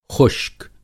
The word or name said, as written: ख़ुश्क